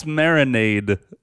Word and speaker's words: marinade: Because for some reason, at first when I watched it, I was like, it's marinade. – Marinate. – No, it's a D, not a T.